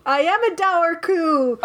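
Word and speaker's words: Dobharchú: I think that's how you say it. Dour coo. It's spelled D-O-B-H-A-R-C-H-U with a little accent ague on it. – It's dour coo. – Yeah, but it's dour coo. There's a B in it that you don't say because it's Ireland, so why not? – Silent B.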